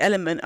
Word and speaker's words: LMNT: Like, unless I'd read somewhere that it's so L-M-N-T, but you pronounce it element, I'd have been like, LMNT? – Yeah.